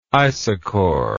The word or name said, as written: isochor